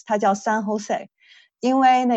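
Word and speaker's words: San José: 所以我以前是说以为这个是 Jose，但是其实它是 Jose，那个元音是要发出西班牙的元音来的。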